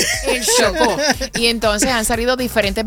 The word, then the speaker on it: Chocó: El Chocó, ¿lo dije bien? – Sí.